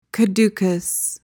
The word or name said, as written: caducous